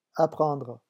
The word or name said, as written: apprendre